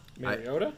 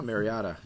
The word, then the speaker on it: Mariota: Mariota? – I hear. No, Mariota. You're saying it wrong.